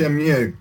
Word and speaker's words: Shenmue: Shenmue.